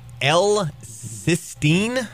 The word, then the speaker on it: L-cysteine: It's uh, an amino acid called L-cysteine.